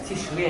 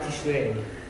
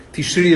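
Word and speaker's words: Tishri (תשרי): Why is it Tishri and not Tishrei? – Tishri is the proper pronunciation.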